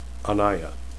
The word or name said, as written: anaiya